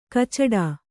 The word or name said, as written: ಕಚ್ಚಡಿ